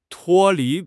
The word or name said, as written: 脱离